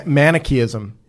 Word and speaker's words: Manichaeism: With Manichaeism.